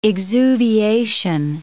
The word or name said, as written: exuviation